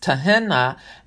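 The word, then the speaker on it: tehinna: So the two nouns, which I believe are pronounced Tahena and Tahanan, are translated supplication.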